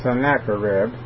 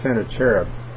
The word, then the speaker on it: Sennacherib: other people pronounce that as Sennacherib. That you may be familiar with that term, but the correct pronunciation of the Hebrew on that is Senator Cherub time.